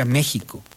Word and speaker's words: México: México se pronuncia México. Y la historia de por qué se pronuncia México es de verdad muy bella, porque tiene que ver con la evolución del sh. Originalmente era México, de los mexicas, ¿no? Y luego se fue evolucionando hacia una J en vez de esa X, pero esa X tiene también como un símbolo.